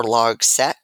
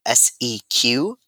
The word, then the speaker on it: Logseq: Like I'm still using Emacs and whatnot for other things, but for this project specifically, I found I'm using Logseq or Logsec, however you want to pronounce it. It's Logseq, and it's meant to be a like on your computer, privacy first, free open source, similar to Rome.